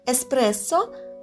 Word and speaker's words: эспрессо: Так, например, происходит с еще одним названием кофейного напитка – эспрессо – эспрессо произносится с удвоенным долгим «с» и пишется практически идентично тому, как произносится.